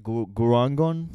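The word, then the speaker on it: Gurgaon: what do you pronounce it? Gurangon? – Yeah.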